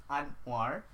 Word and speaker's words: Anwar: And it's easier to pronounce Amirul rather than Anwar. Because I have English people who actually call my name said Anwar. Anwar. I was like, it's kind of annoying. It's like, it's Anwar.